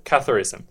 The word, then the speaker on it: Catharism: And he was talking about there was in the Middle Ages, there was, is it pronounced Catharism? – Catharism.